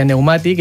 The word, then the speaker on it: pneumàtic: I, per exemple, en pneumàtic no pronunciaríem la P, seria pneumàtic, i de fet la B li dona les dues opcions d'escriptura. Tant pneumàtic sense la P, com, per exemple, pneumàtic amb P sense pronunciar la P. – D'acord, però en cap cas es pronuncia la P.